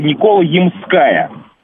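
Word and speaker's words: Никола-Ямская: И вышел у меня спор с одним моим товарищем по поводу ударения, потому что он утверждал, что это Никола Ямская. А углубившись в истории названия улицы, оказывается, оказалось, значит, что Никола Ямская, потому что она названа по э, храму Николы на Ямах.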